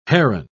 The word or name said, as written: heron